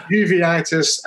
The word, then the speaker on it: uveitis: it's uveitis and eosinophilic esophagitis rheumatoid arthritis, and allergic disease.